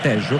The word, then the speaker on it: Tello: l'argentino Facundo Tello, anzi Tejo, considerato che in Sud America la doppia L non si legge Ye ma si legge She, e dunque Tejo va a decretare il calcio di punizione all'altezza della linea mediana in favore della Francia.